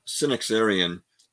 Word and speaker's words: Synaxarion: Let's talk about early Christian history and the Synaxarion. Did I say that right? Yeah, you you said it perfectly. And um, you know the um, the uh, um, Synaxarion is a is a uh, remarkable book.